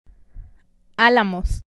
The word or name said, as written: Alamos